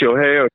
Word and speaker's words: Shohei: Shohei Ohtani. Otani, I can say his last name perfectly. Okay, so yesterday go ahead. – It's Shohei Ohtani.